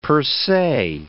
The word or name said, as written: per se